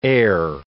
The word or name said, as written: err